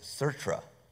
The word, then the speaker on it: Sartre: Here, I looked up the French pronunciation. It's pretty interesting. It's Sartre.